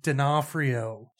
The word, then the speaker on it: D'Onofrio: It's pronounced D'Onofrio.